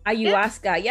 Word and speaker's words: ayahuasca: And just like with the other psychedelics like shrooms and ayahuasca, did I say it right? – Ayahuasca, yep.